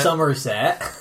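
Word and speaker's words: Summerset: Somerset. – It's spelled differently. – Like summer.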